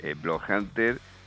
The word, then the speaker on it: Bloodhunter: Estamos vía telefónica con Diva Satánica, vocalista de Bloodhunter. Creo que se pronuncia así, tengo un inglés bastante pésimo sí. Si está mal dicho, me corrige.